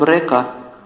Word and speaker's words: рекха: И вот эти линии в санскрите называются рекха, ничем не напоминает слово рекха. Х не читается в санскрите, поэтому река. Санскритное слово река.